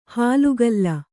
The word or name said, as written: ಹಾಲ್ಗಲ್ಲ